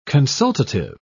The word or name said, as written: consultative